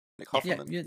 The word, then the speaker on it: Offerman: Nick Offerman.